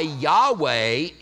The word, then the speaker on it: Yahweh: There is no J in Hebrew. That's why Yahweh is probably the proper way to pronounce it.